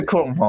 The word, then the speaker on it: Chrome: Chrome 是 Chrome 哦，我一直很怕念错。好，然后呃，最多人用的应该都还是 Chrome，或者现在 Edge 也有慢慢起来的趋势。